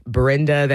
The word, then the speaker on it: Brnda: We are going back to September of last year with the band Brenda. That is spelled B-R-N-D-A.